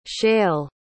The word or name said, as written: shael